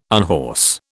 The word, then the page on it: unhorse: British English